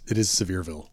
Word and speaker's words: Sevierville: It is Sevierville. Sevierville. – So I nailed it. – Sevierville. This is the... – Not Sevierville. Sevierville. I said it correctly.